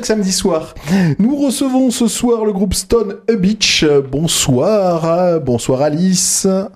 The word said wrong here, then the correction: Stone of a Beach: Je vous ai vu rire quand je lançais le nom, je l'ai mal prononcé. – T'as oublié un mot. – Stone of a Beach. – En plus, j'ai entraîné. – Stone of a Beach. Voilà, je me suis trompé et je m'étais déjà trompé en préparant l'émission dans le mail.